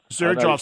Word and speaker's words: Xerjoff: Is it Zirjoff? – Yeah, that's right. Yeah. – Zirjoff spelled... – Spelled funny, yeah. – Yeah, X-E-R-J-O-F-F.